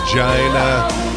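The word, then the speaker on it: Chynna: It's pronounced China.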